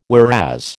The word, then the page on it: whereas: American English